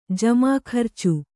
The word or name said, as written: ಜಮಾಖರ್ಚು